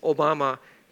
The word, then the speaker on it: Obama: So it's we might pronounce it Obama, but and it's spelled exactly the same as our former president, but it's Obama, Japan, and that's where this was located at.